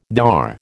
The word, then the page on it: thar: American English